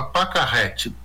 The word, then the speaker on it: Pacarrete: É um filme de 2019, também tem alguns anos, chama Pacarrete, P-A-C-A-Carrete, como se escreve.